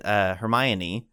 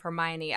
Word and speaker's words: Hermione: Maybe you could tell me, but Hermione, mm-hmm.